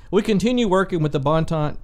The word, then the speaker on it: Boynton: Boynton.